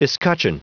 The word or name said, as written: escutcheon